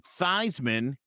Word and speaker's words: Theismann: But his name was actually Joe Theismann, and they started pronouncing it Theismann because it rhymed with Heisman.